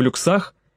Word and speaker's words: люксах: Эти господа, товарищи», — кивнул он в сторону пирса, — «поплывут в первом классе и в люксах», — здесь он сделал ударение на втором слоге, — «а вы, герои войны, да чтоб во втором?» «Нет, так не пойдет».